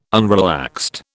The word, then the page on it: unrelaxed: British English